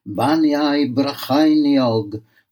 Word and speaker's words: Bannau Brycheiniog: The Welsh name means the peaks of Brecon's kingdom and is pronounced Banau Brecheiniog with a ch making the same sound as in loch.